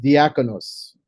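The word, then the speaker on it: diakonos (διάκονος): It's coming from a Greek word pronounced diakonos.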